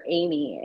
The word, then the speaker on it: Aimee: So it's uh, at adoption underscore Aimee, and my name is spelled different, so because I'm special.